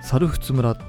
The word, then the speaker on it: 猿払村: あのお猿さんの猿に払う村と書いて、猿仏村っていうんですよ。